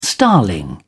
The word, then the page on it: starling: British English